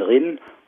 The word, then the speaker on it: Rhein: Also Rhein spricht man im elsässischen Rinn